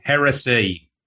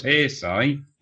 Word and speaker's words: heresy: Heresy. – No, hearsay. – Heresy it's pronounced. The Horus heresy, not the Horus hearsay.